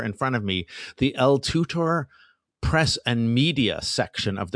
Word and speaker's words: L2TOR: L2TOR should be pronounced El Tutor.